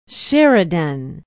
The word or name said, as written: sheridan